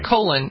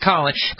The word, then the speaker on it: Colin: Never colon. It's never colon. It's never your intestine. – Colin, it's always Colin.